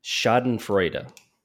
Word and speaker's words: Schadenfreude: But according to the German pronunciation, Schadenfreude.